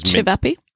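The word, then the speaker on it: ćevapi: They got a chicken leg, they got sea trout, and they got a beef zavapi, which is chivapi.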